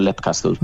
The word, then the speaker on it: Leap Castle: Fíjate, yo diría Leap Castle en su día, pero como Sean Ryan lo pronuncia Leap Castle, yo me quedé con Leap Castle.